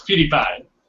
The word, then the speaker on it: PewDiePie: It's PewDiePie.